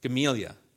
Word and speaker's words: Gamaliel: What is it? How do you say it? Gamaliel. That's it. I always mess it up.